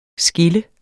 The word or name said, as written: skilt